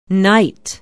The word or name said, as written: knight